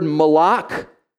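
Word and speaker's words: malak (מלאך): And the word in Hebrew is the word malak. Everybody say malak. Some of you said it right. You got to spit when you say that. When you say a Hebrew word, you got to spit, okay?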